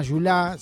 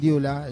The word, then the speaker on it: Diula: El idioma Yulá, se pronuncia así, el Diula, el Yulá,